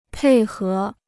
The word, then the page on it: 配合: pèi hé